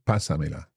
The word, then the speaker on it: pásamela: So that's to maintain the stress on that first syllable. Pásamela. – Eso es. Yes. – Because you look at that and you see P-A-S-A-M-E-L-A.